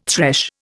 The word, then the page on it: thresh: Indian